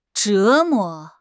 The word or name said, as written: 折磨